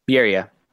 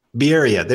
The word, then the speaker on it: Bierria: Did I say that right? Bierria. Bierria. There we go.